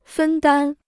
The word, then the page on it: 分担: fēn dān